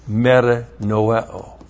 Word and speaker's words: metanoeo: He metamelomide, but he did not metanoeo. M-E-T-A-N-O-E, long O.